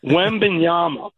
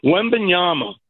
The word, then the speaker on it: Wembanyama: This has been, and that went by, that let me say it correctly, Wembanyama. Wembanyama.